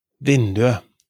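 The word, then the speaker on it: vinduet: At the pronunciation, it's vindue, the window. So for definite neuter nouns, we don't pronounce the t. We write et, but we pronounce it e.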